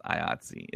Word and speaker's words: IATSE: and that's how people I've come to find out pronounce it. It's IATSE.